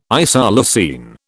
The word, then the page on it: isoleucine: British English